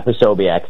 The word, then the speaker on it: Posobiec: Yeah, Posobiec.